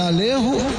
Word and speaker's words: Alejo: No caso do goleiro argentino, o Alejo, é Alejo, Alejo, né? Porque em espanhol é chamado, o J é pronuncia como R, então Alejo.